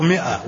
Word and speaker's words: مائة: لا هي تنطق مائة وتكتب بالألف.